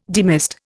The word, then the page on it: demist: Indian